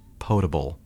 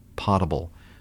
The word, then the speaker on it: potable: but the word is potable, not potable. Okay, it's spelled like, look at the word, P-O-T-A-B-L-E. It's spelled like potato, okay? It doesn't have two T's. It's not P-O-T-T-A-B-L. It's potable.